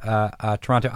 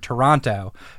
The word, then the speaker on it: Toronto: It's pronounced Toronto. – Like more like Toronto or Toronto. – Toronto. The it's like there's no first O and the second T is silent, apparently.